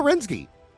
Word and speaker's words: Renczi: Well, it's spelled R-E-N-C-Z-I. – Right, but it's Romanian, so you know it's got that ski sound. – Yeah, okay. Let's go Renitsky.